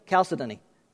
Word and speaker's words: chalcedony: By the way, I did look that up to try and figure out how to pronounce it, and that's what the lady kept saying, chalcedony. No, I said, no, chalcedony, chalcedony. Okay.